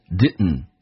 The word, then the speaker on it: didn't: I didn't eat it，这个 didn't 这个单词要记住，在 dn 组合在加速，经常啊美国人喜欢 d 不发音，变成 didn't 气流阻塞一下，就是我们讲的 didn't 这个单词。